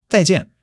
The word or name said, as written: 再见